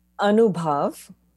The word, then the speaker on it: Anubhav: All right, you say it properly and tell us what it means. – Anubhav.